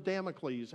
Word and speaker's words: Damocles: Is that how you say it? Damocles? Yeah, the sword of Damocles hanging over their head.